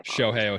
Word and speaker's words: Shohei: I never know how to pronounce his first name. My Shohei Ohtani. – Shohei yeah. Ohtani.